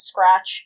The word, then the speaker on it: SCRTC: She is an athlete sponsored by Scratch, or SCRTC, which I am fairly certain is meant to phonetically be said as Scratch, given their logo and the fact that they're all cats.